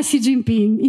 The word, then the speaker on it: Xi Jinping: allora, in italiano è Xi Jinping, in cinese è Xi Jinping.